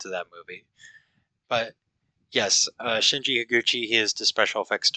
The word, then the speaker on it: Shinji Higuchi: Shinji Higuchi.